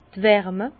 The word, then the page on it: d' Wärme: Bas-Rhin; Herrlisheim